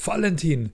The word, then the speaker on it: Valentin: Gerade die Bayern, die sagen doch immer, das heißt Karl Valentin, das wird mit wie F gesprochen, Valentin, das ist ein bayerischer Volkssänger. Man sagt ja auch Volk und nicht Volk, ja? Karl Valentin, das ist ein Eigenname, das spricht man so.